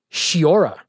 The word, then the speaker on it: Sciorra: And he gave a lot of credit to Annabella Sciorra. – Sciorra? – Is how he pronounced it.